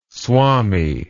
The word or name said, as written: swami